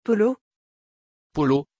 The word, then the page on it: paulo: French